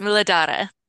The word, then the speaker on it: Muladhara: Let me see if I can say this right. Muladhara. – That's very close. Muladhara. – Muladhara.